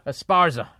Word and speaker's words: Esparza: This episode features John Heffron, Jackie Cashane, I'm the worst reader ever, Dean Del Rey, myself, Al Madrigal, and Felipe Esparza. You got to listen to him. Did I say his name right? – Esparza.